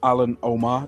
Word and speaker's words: Omer: But anyway, the next fight was Alan Omar, they were pronouncing it even though it's spelled Omer, and Ryan Scope, which was a catch weight.